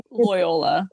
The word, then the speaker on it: Loyola: Loyola.